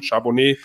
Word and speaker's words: Charbonnet: Aber ansonsten, wie gesagt, sehe ich bei den Runningbacks auch einen, einen, einen Zach Charbonnet, äh, der eine ideale Größe hat und auch sehr schnell war, wenn er richtig ausgesprochen wurde. Das klingt für mich wie ein Rotwein, Charbonnet.